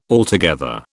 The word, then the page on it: altogether: British English